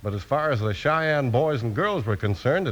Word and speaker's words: Cheyenne: It's spelled C-H-E-Y-E-N-N-E, and it's pronounced Cheyenne.